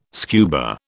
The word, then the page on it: scuba: British English